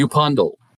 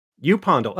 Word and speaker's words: Upondle: Upondle. – Upondle. Okay. A U, like the actual long U.